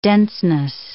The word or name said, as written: denseness